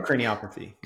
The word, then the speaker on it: craniopathy: And then, am I pronouncing it correctly? Is it craniopathy? Is it craniopathy? Does it matter? – Oh, craniopathy. Yeah. It's a craniopath. And then it's craniopathy is the...